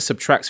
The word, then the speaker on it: SBTRKT: Go listen to Subtract's first album. It's spelled S-B-T-R-K-T.